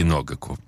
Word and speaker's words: Ногаку: Вот, но ну, Ногаку я выучил, что все-таки Ногаку. Э, правильно.